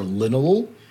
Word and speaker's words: linalool: your terpene friends are linalool, which is also found in lavender. And did you say linalool? – Oops. – Linalool. – Linalool. – It's spelled L-I-N-A-L-O-O-L.